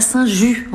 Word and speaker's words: Saint-Just: On est à Saint-Ju. On dit Saint-Ju, mais ça s'écrit Saint-Just.